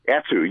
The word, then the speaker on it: Attu: So I believe I read that this is how you got out to Attu. Is that how I pronounce it? – That's right. That's Attu.